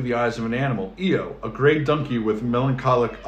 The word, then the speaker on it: EO: It's called EO. Letter E, letter O, EO. – Mm-hmm. – It's about a donkey. – Oh, that's fun. – Yeah. – So it's pronounced EO.